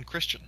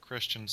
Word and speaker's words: Christian: And down there, they spell it Kristen, but they pronounce it Christian. So we kept the Cajun pronunciation.